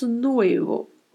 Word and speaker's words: nuevo: Mais uma vez, temos aí uma palavra com a letra V, que sempre terá leve som de B no espanhol. Pronunciamos nuevo. A letra U tem que ser bem pronunciada e a letra E com som de E e o O também mais fechado. O, nuevo, nuevo.